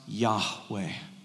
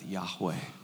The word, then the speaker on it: Yahweh: God's name is four Hebrew letters, often pronounced Yahweh. And that hey, it's a hey in the Hebrew. It has a breath behind it. It's very intentionally spoken with a breath, Yahweh.